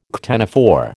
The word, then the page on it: ctenophore: American English